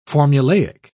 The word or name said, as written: formulaic